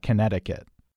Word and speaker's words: Connecticut: Instinctively, I would want to say Massachusetts, but we're talking about silent letters here, so I think we're talking about Connecticut.